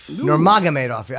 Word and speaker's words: Nurmagomedov: Nurmagomedov, is that how you say his name? – I saw Nurmagomedov. – Nurmagomedov.